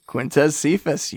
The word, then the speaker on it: Quintez Cephas: Hopefully I'm pronouncing his name right. Is it Quintez Cephas, right? – Quintez Cephas, you got it.